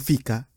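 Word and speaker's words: ficka: Vuelvo a una de las palabras que yo más he usado aquí en el podcast, que es fica. Fica. Así como lo estoy pronunciando, creo yo, que significa es un, no sé, 11, es media 9, es un descanso para ir a comer panecillos y café. Uh-huh. Pero está fica. Y yo no sé si ustedes escuchan diferencia, pero el otro fica es un bolsillo.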